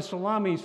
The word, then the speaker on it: Salamis: It looks to me like Salamis. It sounds like a deli, right? Like some of you who are hungry, it's like, I want to go to Salamis for lunch. Sorry to disappoint you. It's pronounced Salamis, and I even researched, is this where salami came from?